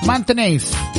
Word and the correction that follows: Maintenance: Lo dije bien en inglés, ¿cierto? Maintenance. ¿Ah? Garden Maintenance. Alonisis Garden Maintenance.